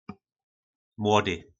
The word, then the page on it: Mlody: po našimu